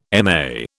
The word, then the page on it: MA: British English